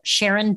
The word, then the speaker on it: Sharon: You can find me on LinkedIn as well, Sharon Delaney McCloud.